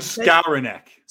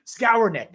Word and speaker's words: Skowronek: I think they even said some Ben Skonowski or something like that. How do you pronounce dude's name? Skonowski? Skowronek? – Ben Skowronek. – Skowronek. That's what it is.